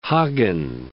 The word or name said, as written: Hagen